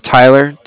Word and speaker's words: Tyler: Yeah, my name is Tyler, T-Y-L-E-R.